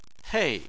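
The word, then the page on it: hei: tone 4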